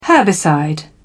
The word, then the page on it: herbicide: British English